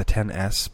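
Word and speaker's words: iPhone XS: It's the iPhone 10, but it's the 10S. But when you look at it, it's spelled XS. And so the joke right now is that it is excessive, so it is the iPhone excess.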